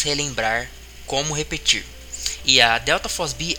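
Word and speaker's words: delta FOSB (ΔFosB): Aliás, é, delta FOSB é que se, é, se escreve IFOSB, mas pronuncias delta FOSB.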